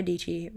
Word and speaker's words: Deechy: Sarah Deechy rhymes with peachy.